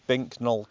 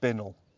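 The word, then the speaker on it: Binknoll: If you try and say this phonetically, it's Bink Knoll Castle, but apparently you're supposed to pronounce it Binnell.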